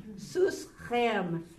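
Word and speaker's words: Sus Chem (סוסכם): I will say, Sus, Chem, Sus Chem, okay? Your horse, Jerry and Bob, your horse, Sus Chem. It is rhyming with atem, which is you, plural, masculine.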